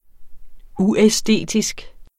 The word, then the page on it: uæstetisk: ˈuεˌsdeˀdisg; ˈueˌ-